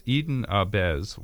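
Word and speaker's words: eden ahbez: Have I pronounced it correctly? – Yes, Eden Abez was what was called a nature boy.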